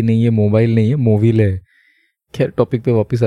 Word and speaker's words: Movile: मोविले केव तो मैंने जब इसके बारे में जब मैं पढ़ रहा था तो मैंने पहले इसे पढ़ा था मोबाइल केव क्योंकि स्पेलिंग में बस वी और बी का अंतर